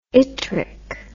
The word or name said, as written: yttric